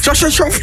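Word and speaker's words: Khachanov: Chasnajov. – Volgens mij was dat vlekkeloos uitgesproken.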